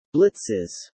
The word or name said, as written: blitzes